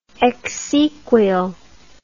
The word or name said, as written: exequial